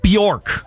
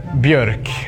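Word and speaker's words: Björk: It's Bjork. Do like this. Bjork. Bjork. It's hard when you don't have those letters. – Yeah. So if we do one more time, we just put it all together? – Viggo Bjork. Viggo Bjork. – It's the accent over the O.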